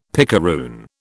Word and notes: picaroon: British English

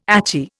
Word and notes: achy: Indian

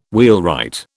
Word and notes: wheelwright: British English

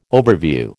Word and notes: overview: American English

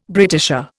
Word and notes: Britisher: Indian

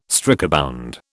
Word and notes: strikebound: British English

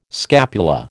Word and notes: scapula: American English

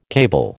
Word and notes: cable: American English

